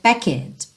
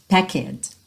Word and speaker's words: packed: Porque a tendência natural do brasileiro, por conta da maneira como o português é organizado foneticamente, é dizer packed, packed. A gente não tem costume de omitir vogal no português. E essa letra E, assim como várias outras letras pintadinhas de cinza, não vai ser pronunciada, tá bom?